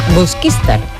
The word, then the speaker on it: Busquistar: Porque sin la gafa no veo el acento y no pronuncio bien la palabra del lugar, de Busquistar. – Busquistar. – Ahora sí lo he dicho bien.